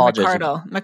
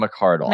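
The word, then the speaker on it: McArdle: Is that how you pronounce her name? – Megan McCardle. – Yeah, Megan McCardle.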